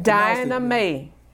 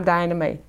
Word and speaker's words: Diana May: My mother pronounced it. It's when I left Louisiana and went to California that the people in California said, that's Diana, that's not Diana. So I just went with it for the rest until I went to Ghana and realized that mothers are always right.